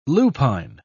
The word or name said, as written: lupine